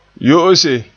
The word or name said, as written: yo'ose